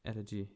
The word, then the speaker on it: Elegy: Wir haben Glenn Close für Hillbilly Allergy. Wird das so ausgesprochen? Allergy. – Allergy. – Elegy, ja.